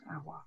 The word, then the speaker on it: Aouar: And as our wonderful Lyon correspondent Tarek Amir has pointed out, if you just listen to, is it Technotronic? That's how you pronounce Aouar.